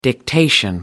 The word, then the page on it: dictation: American English